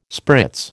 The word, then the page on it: spritz: American English